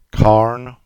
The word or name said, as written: Carne